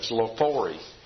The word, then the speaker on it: Xylophory: Festival of Lophori. And that's spelled X-Y-L-O-P-H-O-R-Y.